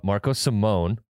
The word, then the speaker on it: Marco Simone: Marco Simone. Simone, not Simone.